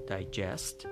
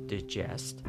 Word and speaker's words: digest: یا دایجست یا دیجست این دو تا تلفظ داره دایجست digest هر دو درست هستن